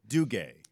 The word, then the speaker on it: Duguay: Duguay.